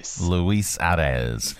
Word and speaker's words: Luis Arraez: Luis Arraez.